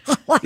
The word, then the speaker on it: Hawaii: Hawaii. You're supposed to say it like you're getting dental work done.